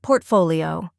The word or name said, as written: portfolio